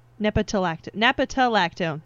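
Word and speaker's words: nepetalactone: And I think it's supposed to be like, I'm thinking like scientific background, maybe like moving the plant releases the nepalactone. – Yeah. – Nepatalactone. There's another syllable in there.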